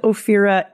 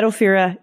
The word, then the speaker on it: Ophira: You can find me on the socials at Ophira E. My name is spelt with a PH in case that's confusing. At Ophira E.